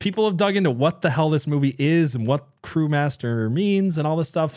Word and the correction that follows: Cremaster: So I'm saying it wrong. Is it Crewmaster or Cremaster? Cremaster, C-R-E Master, Cremaster.